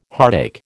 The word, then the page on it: heartache: American English